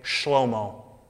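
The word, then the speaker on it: Shlomo (שְׁלֹמֹה): Solomon's name in Hebrew is pronounced, I love this by the way, Shlomo. You're like, what? That's really weird. Yeah, dude was called Shlomo.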